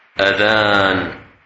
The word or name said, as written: Adhan